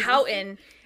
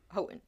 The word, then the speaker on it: Houghton: Houghton... – Oh, we read Houghton, but it's pronounced Houghton.